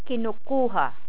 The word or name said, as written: kinukuha